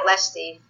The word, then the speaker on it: Lecce: And on one trip, Teresa Manara accompanied her husband and saw the town of Lecce for the first time. And I'm not sure if I'm pronouncing that correctly, L-E-C-C-E. – Sounds good. Think you nailed it.